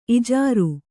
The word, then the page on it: ಇಜಾರೆ: kannada